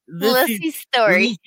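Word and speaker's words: Lisey's Story: next thing we have to talk about is Apple TV Plus's, is it Lissy's story? I feel like I sound lispy when I say it. Anyway, it's Lissy's story.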